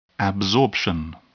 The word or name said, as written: absorption